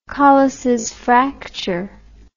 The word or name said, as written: Colles fracture